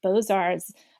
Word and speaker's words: Beaux-Arts: The Beaux-Arts, French term for that's, you know, a new beautiful word.